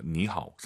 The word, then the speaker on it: 你好: 你好. – 분명히 천천히 발음하시면 니하오지만 사실 삼성이 마지막 글자면 보통 안 올려요. 니하오. 삼성, 삼성이니까 앞에 니는 이성, 니하오.